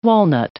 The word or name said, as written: walnut